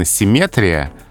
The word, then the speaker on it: симметрия: что по-русски правильно симметрия, но асимметрия.